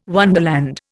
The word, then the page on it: wonderland: Indian